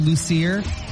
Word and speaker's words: Loser: And their response was so funny because apparently the maiden name was spelled L-O-S-E-R, loser. – Oh. – Although pronounced Lucier.